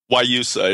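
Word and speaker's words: Guayusa: Ayusa? – Wayusa. It's spelled Guayasa.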